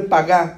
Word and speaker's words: pagá: Es una palabra, así se escribe pagá, P-A-G-A, con acento en la A, la última A.